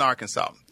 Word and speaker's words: Arkansas: It's Edmundson, Arkansas. Population now about 415. – Okay. Now, do you pronounce it Arkansas? – No, Arkansas.